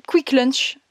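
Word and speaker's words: Kvikk Lunsj: quick Lunch, ça s'écrit K V I K K, Quick Lunch, L-U-N-S-J. Lunch. Voilà, donc on a pris ça, euh, plein de.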